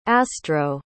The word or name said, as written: astro